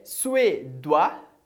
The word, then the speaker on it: suédois: A terceira palavra é suédois.